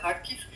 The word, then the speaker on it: Kharkiv: Donc cette ville, grande ville au nord, en français, en général, on prononce Kharkiv, mais en ukrainien, c'est Kharkiv.